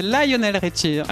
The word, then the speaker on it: Lionel Richie: C'est comme ça qu'on prononce, puisque tu as joué avec lui. – Ah oui. Hein, dans la fosse. – Vas-y. Faut pas se tromper, hein, c'est Lionel Richie.